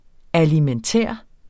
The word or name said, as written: alimentær